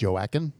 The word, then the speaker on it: Joaquin: I think it's pronounced Joaquin.